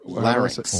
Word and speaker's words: larynx: Larynx.